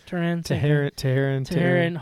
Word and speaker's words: Teherán: Is that how you say it? – Teherán.